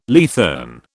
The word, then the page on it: leathern: British English